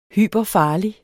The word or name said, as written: hyperfarlig